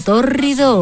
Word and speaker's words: tórrido: Un verano tórrido o un tórrido romance. Este adjetivo es proparoxítono, menudo palabra, no es ninguna enfermedad. Significa que es esdrújula, que lleva el acento en la antepenúltima sílaba. Tórrido.